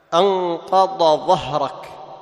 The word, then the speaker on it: أنقض ظهرك: نعم، أنقض ظهرك فتظهر، ما تقول أنقض ظهرك، خطأ، تظهر تبين، كل القراء يظهرون.